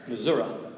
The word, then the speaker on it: Missouri: The correct pronunciation of Missouri is Missouri.